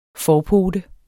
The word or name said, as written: forpote